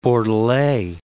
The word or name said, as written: Bordelais